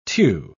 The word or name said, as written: tew